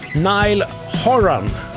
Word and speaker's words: Horan: Dinner Party med artisten vars efternamn är lite olyckligt om man läser det på svenska. H-O-R-A-N. Nile Horan.